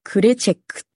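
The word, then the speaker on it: krecek: で、まず一つ目がですね、ちょっとこれ日本人には発音難しいんですけれども、グドゥっていうのと、クレチェックって言います。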